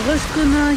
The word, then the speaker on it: Rostrenen: Alors, Rostrenen, il y a eu une grande polémique parce que Rostrenen, en breton, on prononce Rostren.